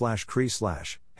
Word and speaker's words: CERI: The Cambridge Existential Risks Initiative, CERI, pronounced /CRI/,